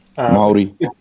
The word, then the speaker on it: Māori: What's the proper pronunciation from a New Zealand point of view? – Māori. – Māori. – Almost like saying Māori. – Yeah. – But it's Māori.